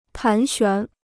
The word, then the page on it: 盘旋: pán xuán